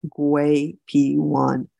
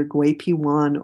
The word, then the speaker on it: Gui Pi: Schizandra, astragalus, and licorice are available together with other adaptogens in a classic Chinese formula called Gui P1, that's spelled G-U-I-P-I, either Gui P1 or Gui P-Tang.